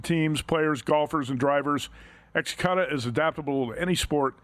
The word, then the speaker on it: Xcutta: If you're looking for a more exciting way to bet, it's called Xcutta. That's spelled X-C-U-T-T-A, and it's the future of futures wagering.